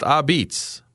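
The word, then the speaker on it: Apizza: Cafe a Pizza, pronounced Abitz, Wyoming Avenue in 40 Fort.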